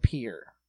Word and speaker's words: Pierre: you're going to use the way we call the capital of South Dakota is spelled Pierre. – Right. – We call it Pierre.